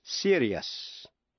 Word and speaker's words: serious: T-I-O-U-S phát âm là shush, như cautious, cautious, ambitious, ambitious và anxious, anxious. Còn nếu trước những tiếp vĩ ngữ I-O-U-S không phải là phụ âm C, T hay X, thì đuôi I-O-U-S phát âm thành hai âm tiết, tức là hai syllables, yes. Thí dụ, serious, serious.